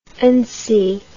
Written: ancy

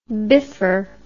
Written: biffer

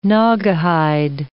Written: Naugahyde

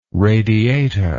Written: radiata

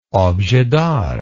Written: objet d'art

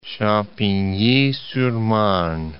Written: Champigny-sur-Marne

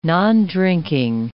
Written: nondrinking

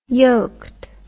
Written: yoked

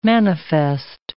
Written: manifest

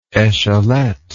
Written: echelette

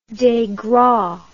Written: degras

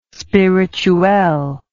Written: spirituel

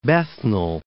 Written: Bethnal Green

